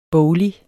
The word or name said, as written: boglig